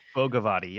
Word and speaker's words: Bhogavati: And they're supposed to, they call it the, I don't know how to pronounce this in English, but it's Bhogavati, something like that. And it's the entrance to Bhogavati, which is Bhogavati. Yep.